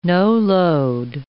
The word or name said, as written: no-load